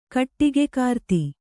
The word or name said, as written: ಕಟ್ಟಿಗೆಕಾರ್ತಿ